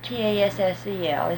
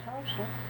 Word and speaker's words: tassel: What about TASSEL? Is it ever pronounced that way? – Tulsa. You call it the tassel.